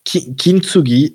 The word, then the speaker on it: Kintsugi: Kintsugi. Okay. Attends, je te l'écris. – Kin comme Anakin. – Ah, Kintsugi comme Tuki, mais quand t'es bourré.